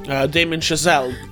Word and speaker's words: Damien Chazelle: Damien Chazelle.